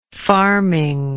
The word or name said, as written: farming